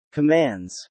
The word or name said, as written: commands